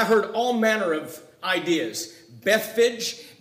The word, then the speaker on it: Bethphage: The majority of scholars say that the way to pronounce the name of this village is Bethphage.